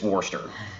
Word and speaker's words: Worcester: Worcester, not Worcester. It's Worcester. – Worcester. – Something like that. – Like Worcester sauce.